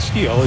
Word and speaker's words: STIHL: That's how you pronounce Steel. It's STIHL, pronounced Steel.